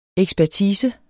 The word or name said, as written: ekspertise